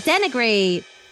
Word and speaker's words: denigrate: Without further ado, today's word of the day is denigrate, spelled D-E-N-I-G-R-A-T-E, pronounced de-ni-grate.